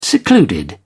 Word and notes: secluded: British English

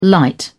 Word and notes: light: British English

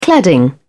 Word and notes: cladding: British English

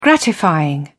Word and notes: gratifying: British English

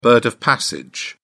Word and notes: bird of passage: British English

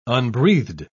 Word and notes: unbreathed: British English

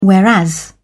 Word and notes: whereas: British English

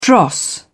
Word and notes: dross: British English

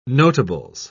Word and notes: notables: British English

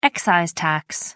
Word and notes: excise tax: American English

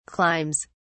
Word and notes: climbs: British English

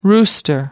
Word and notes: rooster: American English

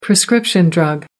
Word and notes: prescription drug: American English

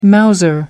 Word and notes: mouser: American English

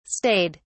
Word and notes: stayed: British English

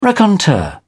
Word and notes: raconteur: British English

